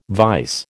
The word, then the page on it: vise: American English